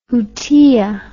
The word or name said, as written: jutia